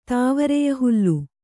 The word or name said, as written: ತಾವರೆಯಹುಲ್ಲು